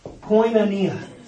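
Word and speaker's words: Koinonia (κοινωνία): Is this word pronounced koinonia? Koinonia.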